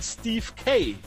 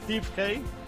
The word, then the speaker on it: Steve Kay: Ich habe vorhin den Kollegen Steve Kai genannt. Er spricht sich aber wohl Steve Kay. – Oh, gut, alles klar. Gominasai. – Er sagt hier ganz klar, da spricht man Englisch aus. Dann werde ich das einfach mal Englisch aussprechen.